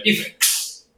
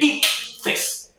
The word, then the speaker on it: ICFES: Recientemente los estudiantes de bachillerato de toda Colombia acaban de presentar las pruebas SABER, anteriormente conocidas como los exámenes del IFEX.